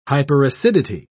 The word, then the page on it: hyperacidity: British English